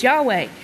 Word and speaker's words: Yahweh: And of course, they debate the way it's pronounced, but the overseer has proven that the name is Yahweh.